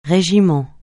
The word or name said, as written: régiment